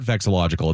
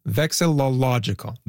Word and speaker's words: vexillological: Well, I'm not even saying it right. Do you know the word vexillological? But it's not vexillological. It's harder to say than that. It's like vexillological. – It's You're missing a syllable. – Hang on. Vexillological. – That's it. Good job.